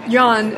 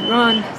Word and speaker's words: yuan: There are not really any English words that work with the correct pronunciation of yuan, but some are closer than others. Wen, W-H-E-N, is probably closer than one.